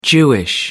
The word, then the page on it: jewish: British English